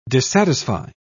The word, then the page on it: dissatisfy: British English